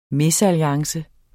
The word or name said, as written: mesalliance